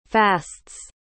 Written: fasts

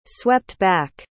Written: swept-back

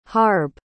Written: harb

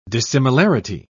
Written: dissimilarity

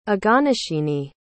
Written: aghanashini

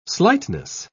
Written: slightness

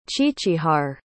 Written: qiqihar

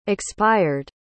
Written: expired

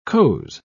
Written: cose

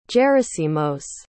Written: gerasimos

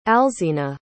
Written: alzina

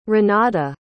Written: renada

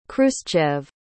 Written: khrushchev

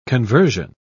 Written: conversion

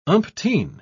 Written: umpteen

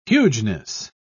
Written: hugeness

